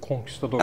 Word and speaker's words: conquistador: Mesela telaffuz edemiyorum. Conquistador mu? – Conquistador. Ha.